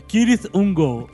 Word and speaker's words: Cirith Ungol: Cirith Ungol, Jovem Nerd, é o rei da pronúncia.